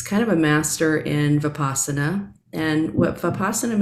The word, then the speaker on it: Vipassana: And what is the, I'm not going to say it correctly, the Vipassana? – Yeah.